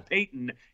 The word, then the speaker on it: Paton: P-A-T-O-N? What the hell is that? Yeah. Actually, it kind of looks like it would rhyme with baton. Like, he should be a George Patton, but apparently he's a Peyton, even though it's P-A-T-O-N.